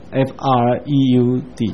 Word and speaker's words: Freud: 希腊文的 EU 这个拼法跟德文刚好颠倒，德文有 EU 读作 OI，像弗洛伊德就是 FREUD 叫 Freud。